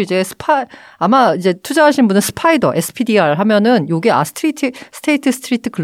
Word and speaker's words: SPDR: 이게 바로 혹시 스파이더라고 들어봤어요? – 아니요. – 이게 그 인덱스 펀드 중에 이제 투자하면은 ETF 투자할 때 되게 많이 보는 건데 SPDR이라고. 근데 이걸 이제 스파이더라고 읽어가지고 이제 거미라고 많이 하거든요.